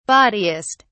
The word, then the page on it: bawdiest: British English